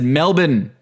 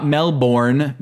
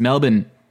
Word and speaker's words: Melbourne: And I said Melbourne, not Melbourne, because that's how you pronounce it. You laugh if you heard an Aussie say Charlotte or Arkansas. So don't call it Melbourne, okay? It's Melbourne.